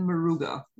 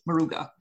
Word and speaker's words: Moruga: Yeah, it came from the Jagasar estate located in Maruga. Okay. Now, for those who may be listening and who may want to locate it on a map instantly, it's spelled Moruga. Okay. Maruga.